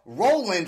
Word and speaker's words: RLND: Today, I have Mr. Joe Mcclune, who's in a band called Roland, right? But it's spelled R-L-N-D.